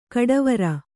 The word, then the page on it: ಘಟ್ಟವರೆ: ghattavare